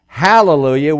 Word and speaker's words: hallelujah: But why is this one pronounced hallelujah with an H? It's because if you notice these breathing marks, this one is different than this one. Now, most songwriters don't see that. Greek has no H in its alphabet.